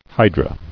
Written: Hydrae

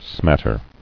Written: Smatter

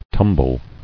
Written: Tumbled

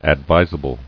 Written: Advisability

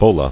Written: Bolas

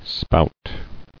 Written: Spouter

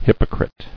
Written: Hypocrite

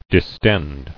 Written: Distensible

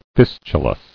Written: Fistular

